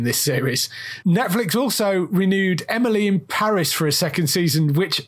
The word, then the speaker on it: Emily in Paris: And like you, I only recently found out that it's meant to be pronounced Emily in Paris. It's meant to rhyme.